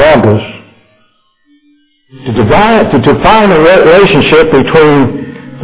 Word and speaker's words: Logos: Fellows, please pronounce that correctly. It's Logos. Not Logos. It's Logos. And the Logos was with God.